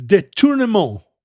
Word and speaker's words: détournement: And they described it as détournement. Détournement. – What does that mean? The tournament? – What does it mean? Détournement. It's all one word. Détournement. So not détournement. – Is it spelled the same way? – Well, T-O-U-R-N-E-M-E-N-T.